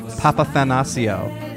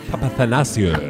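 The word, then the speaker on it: Papathanassiou: Papathanasio. – Papathanasio.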